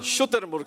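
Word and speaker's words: شترمرغ: شتربرگ صحیح تلفظ ہے.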